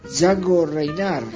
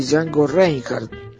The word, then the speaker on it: Django Reinhardt: o como le dicen la mayoría de la gente, Django Reinhardt, pero la pronunciación de Django Reinhardt la tomé del gran Rodríguez Tabeira, que aparte me parece mucho más acertada esa pronunciación que la pronunciación inglesa o americana, ya que él era belga y vivía en Francia.